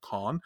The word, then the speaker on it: Cannes: Uh, it did win the Palme d'Or at Cannes or okay. Cannes, Cannes, however you pronounce it. I believe it's technically pronounced Con uh, Film Festival in France.